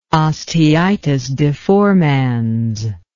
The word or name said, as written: osteitis deformans